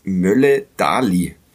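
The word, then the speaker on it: Møller Dæhli: Der Club hat in Karlsruhe gewonnen bei der zuvor Mannschaft der Stunde durch ein spätes Tor von, Achtung, Mats Mölle-Dahli. Habe ich in einem St. Pauli-Podcast Millanton gelernt, dass er so ausgesprochen wird. Ich hoffe, ich erinnere mich richtig. Also das R ist stumm und dann ein Dahli.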